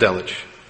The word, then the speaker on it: Delitzsch: This is from Kyle and Delitzsch, or Delitzsch, I believe it's pronounced Delitzsch.